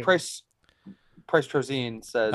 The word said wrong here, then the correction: Trozen: No, short I. – Oh, Trozen. Vin. Got it. Trozen. Sorry. – It's not Tolzine, all right? You damn Wisconsin people. – So I draw that from having people in my life when I was growing up whose last name is without the T, Rosine, R-O-S-I-N. So that's where that's coming from. But okay, we're on to a correct pronunciation.